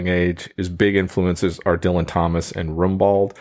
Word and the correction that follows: Rimbaud: I always pronounce it wrong too. – How do you say it? – Rambo. – Rambo. Thank you.